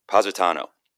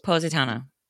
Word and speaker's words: Positano: And then the next day was kind of like a drinking day, but they left to go to, how do you say it? Positano. – Positano. Yeah. It's like phonetic. Positano.